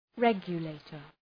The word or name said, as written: regulator